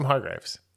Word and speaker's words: Hargrave: Actually, so that's kind of shitty because Chris Hemsworth is a producer on this as well, as is Sam Hargraves. – Anyway. Yeah. Which? Anyway. I'm interested to see what he does, like other than Extraction movie, Sam Hargraves. – I mean, I mispronounced it. It's Hargrave.